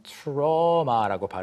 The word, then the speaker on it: trauma: 우리가 그 트라우마라고 하는 게 영어로는 trauma라고 발음이 됩니다.